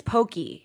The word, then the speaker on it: poke: I feel like it's Poke. – Maybe. – Poke. – Yeah. – I like Poke. The accent's on the E.